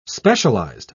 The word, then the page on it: specialised: British English